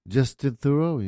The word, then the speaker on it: Theroux: Justin Theroux, you're right.